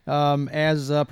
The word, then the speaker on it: Fillion: Yep, Fillion.